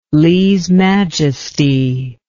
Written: lese majesty

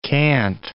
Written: can't

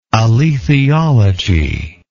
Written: alethiologist

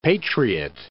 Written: patriot

